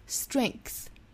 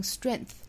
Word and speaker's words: strength: 你永远都不明白为什么要经历你所经历的事儿 until you see the strength 这个词我们也讲到过，就是里面这个字母 G 哈，应该是发清辅音的 k， strength until you see the strength。那当然这个词也是有两种发音，也可以发成 strength，就是不发出中间的这个 k 的发音也是可以的 strength。